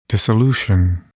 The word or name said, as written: dissolution